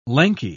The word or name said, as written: lanky